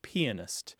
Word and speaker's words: pianist: Well, traditionally, the pronunciation of this word is pianist, with an emphasis on the first syllable, pianist. There is a second pronunciation, which is less common, and some dictionaries don't even include it, which is pianist. But pianoist isn't a word that is typically used by people who play the piano.